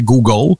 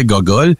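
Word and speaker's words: googol: Exactement, exposant 100, ça se dit, ça a son propre terme et ça se prononce en anglais Google et en français Gogol.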